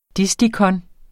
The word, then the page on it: distikon: [ ˈdisdikʌn ]